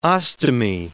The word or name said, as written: ostomy